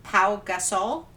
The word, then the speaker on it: Pau Gasol: And Baron Davis and Pau Gasol. Did I say that right? – Yep.